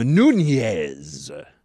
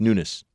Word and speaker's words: Nunes: Very few people say his name properly. They're all like, oh, I want to be, you know, culturally sensitive, so I will call him Nunez. No, it's Nunes.